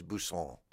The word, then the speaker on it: Busan: I have a somebody I know is going to next year is going to Busan in Korea, to Busan, and I pronounce everything foreign words French. I said, "Oh, you're going to Busan," and there is no place Busan. But I thought, wow, it feels like it wants to be. It feels like there's a silent T. But no, it's just Busan, and I should know that.